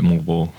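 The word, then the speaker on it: Moalboal: Okay, so the pronunciation I got off the internet is Moal Boal, but you're calling it Moal Boal. So it's whatever you want to call it. Or what? How do the locals say it? Moal Boal? – Yeah, the locals call it Moal Boal.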